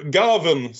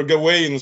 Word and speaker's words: Gawain: Eller mer specifikt legenden om Sir Gavin som jag har lärt mig nu att uttala som filmen. Inte Sir Gawain som jag alltid hade sagt annat och den gröne riddaren.